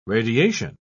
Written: radiation

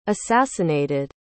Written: assassinated

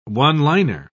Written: one-liner

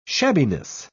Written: shabbiness

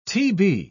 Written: tb

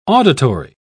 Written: auditory

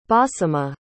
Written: basimah

